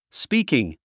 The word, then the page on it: speaking: spíːkiŋ; スピーキン